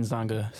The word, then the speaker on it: Nzanga: Nathan Nzanga, silent N in front of the Z.